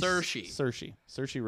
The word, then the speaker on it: Saoirse: How the hell do you pronounce that? – This is like S-A-O-I. – Yeah. It's apparently Saoirse Ronan.